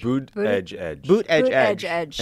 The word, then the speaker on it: Buttigieg: Did I say it right? – Buttigieg. – Boot edge. – And Beto. – Oh, he wants the DGE sound?